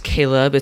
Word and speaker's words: Caleb: And to make it even worse, for those who know what my name is, Caleb, it's spelled C-A-L-E-B.